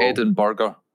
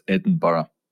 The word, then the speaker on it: Edinburgh: And it's not Edinburgh or Edinburgh. It's Edinburgh.